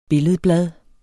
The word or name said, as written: billedblad